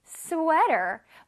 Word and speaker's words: sweater: 근데 우리 스웨터라고 발음하지 않고 어떻게 할 거라고? Sweater. Sweater. Okay. 자, 조금 이제 느끼할 수도 있는데 다시 한번 따라해 봅시다. One more time. Sweater. Sweater. 자, S 부분에서 소리를 내주고요. W-E-A를 길게 내줄 거야.